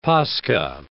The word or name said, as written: Pascha